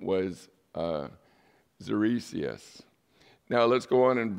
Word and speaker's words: Xerxes: The king that is called Ahasuerus here is also known as King Xerxes. It's spelled X-E-R-X-E-S, but it's pronounced like if you would spell it phonetically, Z-E-R-C-E-S. So Xerxes, but it's spelled X-E-R-X-E-S.